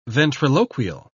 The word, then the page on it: ventriloquial: British English